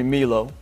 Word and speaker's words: Milo: Well, first name, people call me Milo. You know, and I get that. There's a lot of international folks, so like the MI sound is me.